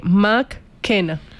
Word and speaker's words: McKenna: Se pronuncia más bien como se lee, McKenna.